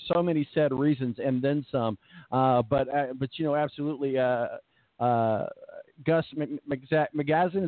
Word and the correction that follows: Malzahn: I think Malzahn.